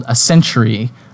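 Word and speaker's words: A(s)century: It is called Ascentury, which is spelled like ascent plus century, like a portmanteau, except the letter S is in parentheses.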